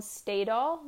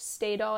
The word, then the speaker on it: Stadol: Just research Stadol. I believe it's spelled Staydol.